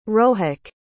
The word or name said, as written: rohak